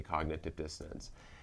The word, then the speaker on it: cognitive dissonance: Cognitive dissonance. – Okay. – Did I even pronounce that right? – Yeah, you sure did. Well, real simply, it's a term from the study of psychology, cognitive dissonance.